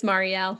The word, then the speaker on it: Marielle: It's Marielle.